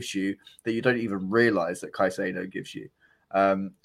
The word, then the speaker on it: Caicedo: Caicedo. – Caicedo.